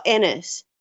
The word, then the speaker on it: Ennis: Oh, Ennis. So tennis without a T. Very easy.